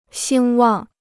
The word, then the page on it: 兴旺: xīng wàng